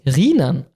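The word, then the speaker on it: Rhynern: Grüße aus Hamm-Rheinern. Hamm-Rheinern. Ausgesprochen wird es Rienern.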